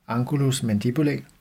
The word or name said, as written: angulus mandibulae